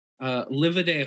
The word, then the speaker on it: Livaudais: Livaday Hall. Yes. I'm probably pronouncing that way wrong, but it is L-I-V-A-U-D-A-I-S. But I definitely prefer live a day, because the irony is beautiful.